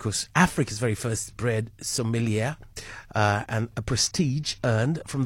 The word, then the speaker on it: sommelier: In the meantime, I'm going to introduce you to South Africa's very first certified bread sommelier. Did I pronounce it right? – Yeah, you did.